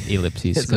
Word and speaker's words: ellipses: I say ellipses. Okay. It just ellipses. – It just sounds cooler. – Yeah. – E is a, it's a long E.